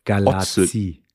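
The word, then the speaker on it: Galați: Das ist äh, in, in Rumänien wird das T auch so ausgesprochen. Ich war in Otelul Galaz, Galati, das heißt aber Galazzi.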